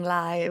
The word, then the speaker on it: Lai: Or like I say to people now, like, I sing Lai, which is like, I might have not pronounced that the best, but saying like my last name is Chinese.